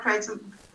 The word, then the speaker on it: kratom: If I buy kratom, sorry, I kept saying it wrong. Kratom from uh, another vendor.